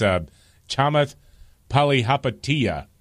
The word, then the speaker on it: Chamath Palihapitiya: Yeah, it's Chamath Palihapitiya. Sure. – Yes. – Nailed it.